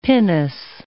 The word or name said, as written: pinnace